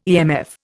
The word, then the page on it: EMF: Indian